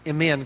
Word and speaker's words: Mene: Literally, the words mean, and it says M-E-N-E is actually pronounced M-N, kind of like the consonants M and N put together, M-N.